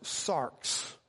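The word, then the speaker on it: sarx (σάρξ): The word flesh in Greek is a word that's pronounced sarx.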